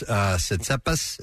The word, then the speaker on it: Tsitsipas: And you'll have to help me because I don't follow tennis, but Stefanos Tsitsipas, is that how you say his name? – Yeah, let's go with that. – Tsitsipas.